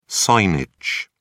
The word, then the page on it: signage: British English